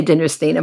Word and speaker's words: Dinnerstein: It depends on which part of the family is speaking, but we say Dinnerstein in my Okay, great.